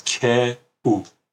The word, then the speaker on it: کو: So K Aleph Vav is pronounced ku because it's a contraction of two words, K U.